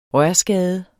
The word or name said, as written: rørskade